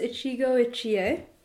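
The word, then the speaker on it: Ichigo Ichie (一期一会): So it's pronounced Ichigo Ichie, and it's a Japanese four-character idiom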